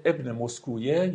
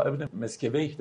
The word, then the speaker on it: ابن مسکویه: و یکی از کسانی که در این باره خیلی جدی کار کرده ابن مسکویه یا ابن مسکویه در واقع هست، هر دو تلفظ درسته، که او خیلی دقیق واقعا به این موضوع اشاره کرده و عالی.